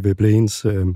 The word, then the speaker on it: Veblen: Og det ligger der i Veblens... han var egentlig en norsk ekstraktion, så jeg tror, det er Veblen. – Ja, det er udtalen.